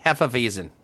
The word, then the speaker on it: Hefeweizen: Hefeweizen.